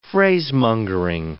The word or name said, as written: phrasemongering